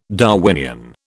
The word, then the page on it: Darwinian: British English